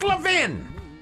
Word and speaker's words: Levin: Mark Levin's website, and I often agree with Mark Levin in terms of his view. – It's Mark Levin. – His pronounce it Levin.